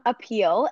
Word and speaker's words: Apele: So Stevie is here from Apele.